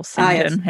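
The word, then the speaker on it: St John: Some of the famous ones include like what looks like St. John is pronounced Sanjin or Sinjin here.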